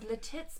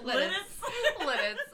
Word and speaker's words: Lititz: At Speedwell Forge, a quiet haven just north of the town of Lititz, PA. – Lititz. – I know how to say it. I just wanted to say Lititz.